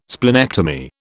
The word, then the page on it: splenectomy: British English